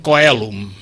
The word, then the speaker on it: coelum: En canvi, tornant al quèlum aquest, els a Roma, la C aquesta deien coelum. Coelum. Aquesta és la pronunciació clàssica que jo intento fer amb molt poc èxit moltes vegades perquè fico la pota, però d'això es pot dir lectio difficilior.